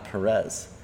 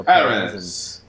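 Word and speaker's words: Pérez: It truly is Sergio Perez, not Perez. The accent is on that first E. So I'm gonna say Sergio Perez, and people are gonna accuse me of pretending to speak with a British accent.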